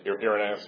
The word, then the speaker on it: Irenaeus: How may you pronounce it? – Irenaeus, yeah. Mm-hmm.